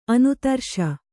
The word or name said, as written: ಅನಾದರ್ಶ